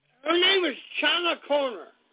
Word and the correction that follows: Conner: C-H-A-N-A. And her last name is spelled C-O-N-N-E-R, Connor. – Oh, Connor.